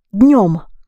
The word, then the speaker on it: днём: And to say in the afternoon, you say in the daytime or with the daytime, днём. That is how the word день changes in with situation. Днём, д-н, днём, becomes a nasal sound.